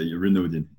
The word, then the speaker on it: Renaudin: Yeah, it's fine. You can say Renaudin. It's the English version.